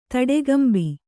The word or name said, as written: ತಡೆಗಂಬಿ